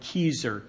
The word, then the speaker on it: Keyser: It's spelled like Keyser, K-E-Y-S-E-R.